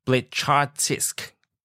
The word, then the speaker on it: Blecharczyk: Nathan Brykus. – Okay, this is an Eastern European name. So Nathan Blechartsisk.